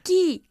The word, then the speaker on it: key: ¿Cómo se dice tecla? – Kate. – ¿Cómo se pronuncia tecla? – Key. – Key, mucho mejor.